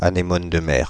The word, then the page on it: anémone de mer: Paris; [a.ne.mɔn də mɛʁ]